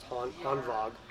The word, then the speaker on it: Hanwag: So it's actually spelled Hanwag, but I believe it's pronounced Hanwag. So if you don't, if you spit that vog out, you're going to get that out like the Germans.